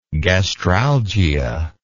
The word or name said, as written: gastralgia